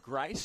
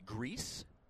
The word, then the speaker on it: Greise: Grice spells his last name G-R-E-I-S-E, but pronounced Grice. I would think a lot of people would say Greece.